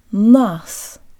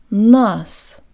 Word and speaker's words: нас: Let's start with good Russian default mouth position. Jaw slightly forward, tip of the tongue behind the bottom teeth, lips rounded and protruded. Нас. Нас.